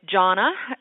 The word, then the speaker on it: Gianna: Gianna, if you're in Italy, it's Gianna, uh-huh.